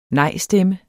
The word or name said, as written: nejstemme